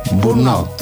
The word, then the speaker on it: burnout: justamente vamos a hablar del síndrome de burnout. O no sé cómo se pronuncia, ¿está bien pronunciado? Burnout. – Sí.